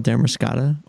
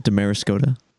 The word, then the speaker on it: Damariscotta: A little town called Damariscotta, or as the GPS likes to pronounce it, Damariscotta.